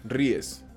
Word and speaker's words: Ries: no empezó en el libro de Lean Startup de Eric Rice, Eric Ries, no sé cómo se pronuncia, pero él lo, popularizó. Ries. Ries, Eric Ries y Eric este, Eric Laughter.